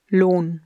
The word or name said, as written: Lohn